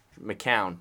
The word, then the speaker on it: McCown: The Seahawks hate to put away games, even when they're better than the other team, and they have Josh McNown playing quarterback. McCown, not McNown. I don't even know how to say his name.